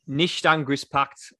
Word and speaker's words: Nichtangriffspakt: Nichtangriffspakt, I reckon that's how it's pronounced. – That was pretty good.